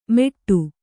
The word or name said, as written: ಮೆಟ್ಟು